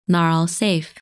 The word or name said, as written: Nair al Saif